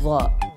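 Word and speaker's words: Dha (ظاء): Next word is dha. – Say that again. – Dha. – What? – Dha. It makes the dha sound.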